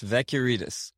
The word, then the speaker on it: Vekiarides: It's Vekiarides.